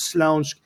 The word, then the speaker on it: Śląsk: in a region properly pronounced in Polish as Śląsk, and the city was Świętochowice, which is this little place by Katowice, which is a big steel city.